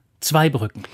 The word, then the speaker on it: Zweibrücken: Aber Zweibrücken lautet Zweibrücken und nicht Zweibrücken.